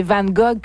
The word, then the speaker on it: Van Gogh: J'ai dit Van Gogh pour faire comme si j'étais pas parfaite, mais c'est Van Gogh.